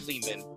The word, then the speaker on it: Lehman: It's me, Ari Lehman. Rhymes with demon.